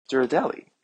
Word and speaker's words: Ghirardelli: Ghirardelli.